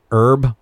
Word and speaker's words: herb: And we have an H in front of an herb. – Oh, yeah. I think we pronounce that H. We say herb.